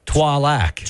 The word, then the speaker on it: Trois Lacs: You don't pronounce the S. Trois Lacs.